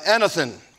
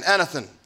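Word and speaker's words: anothen: And it's literally, it means, uh, it, it's, it's pronounced uh, anathen. It's A N O T H E N, anathen.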